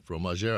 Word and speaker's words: fromager: He's the how do you pronounce the, the head fromager? Yeah, fromager.